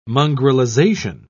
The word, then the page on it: mongrelization: British English